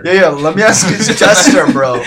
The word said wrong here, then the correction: gesture: Bro, am I saying it right? – Who's jester? It's gesture.